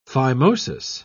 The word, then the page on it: phimosis: British English